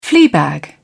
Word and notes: fleabag: British English

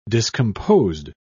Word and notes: discomposed: British English

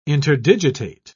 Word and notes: interdigitate: British English

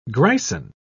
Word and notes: grison: British English

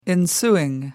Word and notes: ensuing: American English

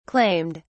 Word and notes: claimed: British English

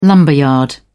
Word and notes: lumberyard: British English

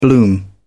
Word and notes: bloom: British English

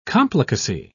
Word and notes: complicacy: British English

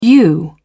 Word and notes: yew: British English

